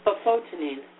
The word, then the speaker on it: bufotenine: And then bufotenin. How would you pronounce it? Bufotenine, yeah. – Yeah, okay.